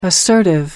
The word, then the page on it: assertive: American English